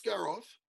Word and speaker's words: Skarov: Because I got done talking about Alex Skarov. – Yep. – You know why I'm saying his name right? – Yes, because he wrote it phonetically.